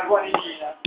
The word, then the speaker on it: guanidina: Allora, questo è il gruppo della guanidina.